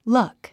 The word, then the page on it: luck: lʌ’k; ラァック